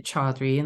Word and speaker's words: Chaudhary: Chaudhary, and the last name is spelled Chaudhary, .com.